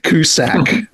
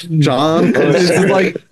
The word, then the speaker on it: Cusack: And then we have Julianne Moore, who's kind of Cusack. That's how it's said. John Cusack.